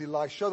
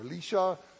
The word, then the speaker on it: Elisha: The second one is Elisha the prophet, or Elisha, as some would pronounce it.